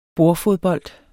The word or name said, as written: bordfodbold